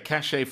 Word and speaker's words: cachet: Yes, cachet from the French, so we don't pronounce the T on the end. C-A-C-H-E-T, cachet. It just means that that state of having something.